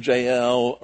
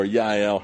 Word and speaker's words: Jael: So, and you can pronounce her name Jael or Yael.